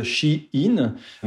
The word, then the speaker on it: Shein: C'est à cette époque que le nom a été raccourci en Shein, alors qu'il faut prononcer du coup à l'anglaise Shein.